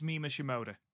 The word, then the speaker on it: Mima Shimoda: Am I saying that right? Mima Shimoda? – I believe it's Mima Shimoda.